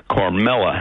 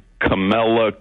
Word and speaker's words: Kamala: But I just want to talk about this heartless ideologue, Kamala Harris, or as Tucker appropriately mispronounces her name, Carmela Harris.